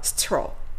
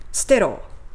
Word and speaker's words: sterile: で、この滅菌する、滅菌のという形容詞は、ステロというふうに発音することになります。ステロ、ステロ、ステロ、ステロ。こんな感じですね。カタカナで言うならば、ステロのような発音をします。